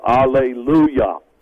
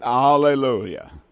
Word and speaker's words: alleluia: I know, I remember being in some of the Arab countries, and the way that they pronounce hallelujah, they would say alleluia. – Yep, alleluia.